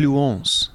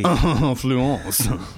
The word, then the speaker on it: Fluence: The Fluence. – Oh, sorry. Yes, correct pronunciation. Very good. – Oui. – Fluence.